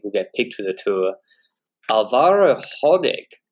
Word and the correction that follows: Hodeg: or Hodge rather, is how he likes to be pronounced.